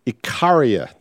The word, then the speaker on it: Icaria: So anyway, we're sailing across to Icarus, or Icaria, I should say.